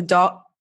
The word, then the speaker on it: dove: I also really like the idea of the dove. How do you say dove or dove? – Dove. – Dove. Okay.